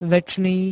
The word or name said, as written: večný